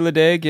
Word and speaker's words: Ledaig: I know I'm going to pronounce this wrong, but I think it's Ledaig. Ledaig. Maybe Ledaig. – I would say Ledaig, yeah. – Ledaig.